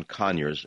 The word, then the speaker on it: Conyers: John Conyers, emphasis on the first syllable.